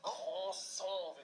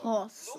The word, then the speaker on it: Croissant: Croissant wird das ausgesprochen. – Croissant.